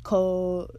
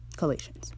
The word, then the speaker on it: Colossians: Colossian. Colossians.